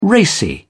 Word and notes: racy: British English

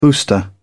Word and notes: booster: British English

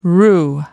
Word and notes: roo: American English

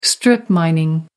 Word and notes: strip mining: American English

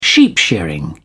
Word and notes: sheep shearing: British English

